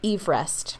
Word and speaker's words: Everest: Everest, because it's named after a person whose last name was Everest. Like, together.